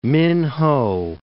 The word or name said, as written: Minhow